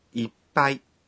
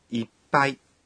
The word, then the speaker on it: いっぱい: いっぱい的发音有点难。 – いっぱい这个词里有促音，促音用小的つ来表示，发音的时候要屏住呼吸，停顿一拍。好，我们来练习一下。 – いっぱい。